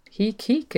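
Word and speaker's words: Heke: So Heek, it rhymes with Reek.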